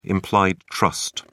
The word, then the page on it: implied trust: British English